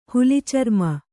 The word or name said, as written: ಹುಲಿಚರ್ಮ